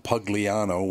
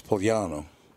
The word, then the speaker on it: Pugliano: John Pugliano, or he might pronounce it Pugliano, which is not the Italian way to say it. It's Pugliano.